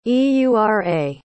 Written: eura

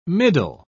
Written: middle

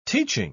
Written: teaching

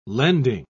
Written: lending

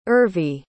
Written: urvi

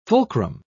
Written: fulcrum

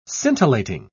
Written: scintillating

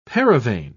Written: paravane

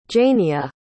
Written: Jania

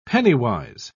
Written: penny-wise

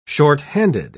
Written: shorthanded

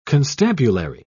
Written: constabulary

